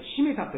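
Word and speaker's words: 七五三太: で、幼少の頃の名前は新島ジョーじゃなくて七五三と書いてしめたと読むそうです。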